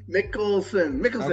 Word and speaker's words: Mickelson: Mickelson? – Mickelson.